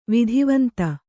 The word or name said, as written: ವಿಧಿವಂತ